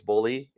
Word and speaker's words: Bohle: First of all, even though his name's not spelled B-U-L-L-Y, it's spelled B-O-H-L-E. And I was informed by his family it's Bulley.